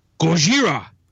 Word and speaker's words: Gojira: You're not saying that right. – Is it how do you say it? I don't even know. – Gojira. – Oh, Gojira! All right, have you heard them? – It's the way the Japanese say Godzilla.